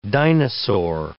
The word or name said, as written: dinosaur